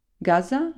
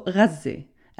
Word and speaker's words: Gaza: One, Wasim pronounces Gaza a couple different ways. Gaza, like we say in English, and also Ghazi, as it's pronounced in Arabic.